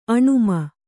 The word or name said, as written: ಆಣ್ಮ